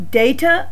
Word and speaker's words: data: Data or data. Both pronunciations are correct.